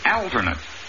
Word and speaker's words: alternate: The attorney became an alternate on the grand jury. – The word is alternate. The first A is short, and the accent is on the first syllable.